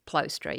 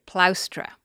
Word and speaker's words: plaustra: But anyway, this character comes along and says, no, you don't pronounce it plostra, you pronounce it plaustra.